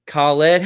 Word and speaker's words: Khaled: DJ Khaled, how do you pronounce that word? – Khaled.